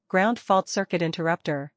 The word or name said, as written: GFCI